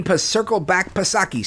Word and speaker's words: Psaki: But we were talking about climate and Joe El Baboso Biden and his right-hand woman, Jen Psaki, silent P, of course.